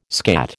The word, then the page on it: skat: American English